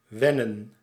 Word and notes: wennen: [ˈʋɛnə(n)]

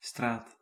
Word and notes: channel: /ˈtʃæn.əl/

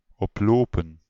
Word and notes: grossir: France; [ɡʁɔ.siʁ]

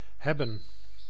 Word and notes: hebben: /ˈɦɛ.bə(n)/

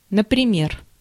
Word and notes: например: /nəprɪˈmʲer/; naprimer

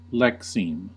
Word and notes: lexeme: American English; /ˈlɛksiːm/

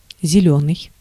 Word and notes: зелёный: [zʲɪˈlʲɵnɨj]

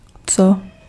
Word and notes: quel: [kɛl]; France

